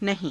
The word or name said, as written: nahii~